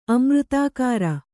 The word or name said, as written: ಅಮೃತಾಕಾರ